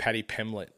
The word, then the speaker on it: Paddy Pimblett: We're talking about UFC, and what we're talking about is uh, Patty Pemberton, right? Did I say it right? – Paddy Pimblett. Yeah, Paddy Pimblett.